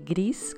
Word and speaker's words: gris: Na França é Pinot Gris Escreve gris com S no final, pronuncia gris. Os franceses não falam o S.